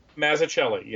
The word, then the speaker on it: Mazzucchelli: Is it Dave Mazzucchelli? – Mazzucchelli, yes.